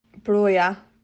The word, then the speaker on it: Broja: Broja.